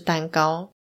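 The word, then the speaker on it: 石高: 简单来说，就是指丰臣秀吉对日本的农地进行大小的测量，还有用担高作为收获量的计算。诶，那个担高不是生日吃的那个蛋糕哦。它是石头的石，高度的高。因为“ 石” 这个字呢，用于度量的话会念作“ 担”，所以整个词念起来就是“ 担高”。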